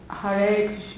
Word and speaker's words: Hare: In Sanskrit, the A is pronounced. And so it's Hare Krishna, Krishna, Krishna, Hare Hare, Hare Rama, Rama, Rama, Hare Hare.